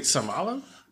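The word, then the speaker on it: Seumalo: Is Isaac Samalo? – Yeah. – Good pronunciation.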